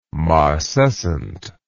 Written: marcescent

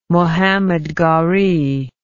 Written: muhammad ghori

